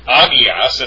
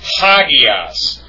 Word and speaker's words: hagias: And this form is not pronounced agias, it's pronounced hagias, with a hard H sound, hagias.